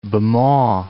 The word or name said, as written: Bhamo